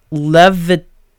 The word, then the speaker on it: Leviticus: Levit, Levit, I can never pronounce it, Leviticus, Leviticus. Okay, there we go.